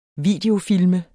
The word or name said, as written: VIDEOFILME